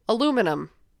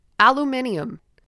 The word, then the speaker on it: aluminium: First off, aluminium. Mm-hmm. Yep. Americans, aluminum. No. Aluminium. I can't even,